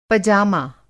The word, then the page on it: पजामा: Hindi